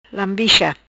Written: Lambicha

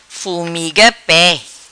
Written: Fumiga Pe

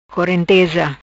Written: Corenteza

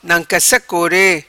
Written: Nancassa Core

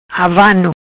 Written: Avano

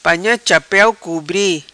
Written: Panha chapeu cubri

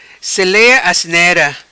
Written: Selea Asnera

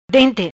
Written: Dente

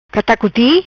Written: Catacuti

